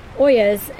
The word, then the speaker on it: ollas: And so speaking of cooling off and watering, is we wanted to talk today about Ollas, and that's spelled O-L-L-A-S.